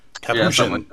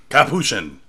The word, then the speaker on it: Kapiushon: It's season 5 of Arrow, episode 17, called, I don't remember how I pronounced it last week. What is it? Kapiushon? – Kapiushon. Yeah, Kapiushon.